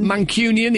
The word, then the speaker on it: Mancunian: We've made a decision that every time we come to visit you, we're going to play one of our favourite Mancunian songs. – Yeah, well, last time... Did I say that right, Mancunian? – Mancunian?